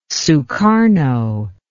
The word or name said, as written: sukarno